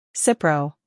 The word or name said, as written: Cipro